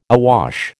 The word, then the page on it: awash: American English